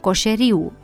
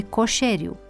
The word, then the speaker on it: Coșeriu: Deplasarea accentului a apărut și în pronunțarea numelui unui mare lingvist, coșeriu, în loc de coșeriu, care provine din substantivul comun coșer, variantă regională a lui coșar, persoană care face sau și vinde coșuri.